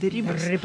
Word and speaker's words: де Рибас: генерал-майор русской армии Дон Хосе де Рибас, вот ударение надо правильно ставить, де Рибас, взял турецкую крепость Хаджибей, которая была будущей Одессой.